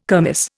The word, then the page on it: kermis: Indian